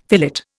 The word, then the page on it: fillet: Indian